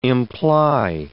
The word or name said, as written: imply